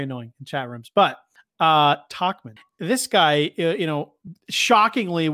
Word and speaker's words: Tauchman: Mike Tauchman. Oh, my God. – I got to correct you for our boy Alex. It's Tauchman.